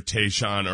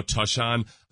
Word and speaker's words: Tashaun Gibson: Various pronunciation guides say either Tayshawn or Tushon. – And I think he's saying now Tushon.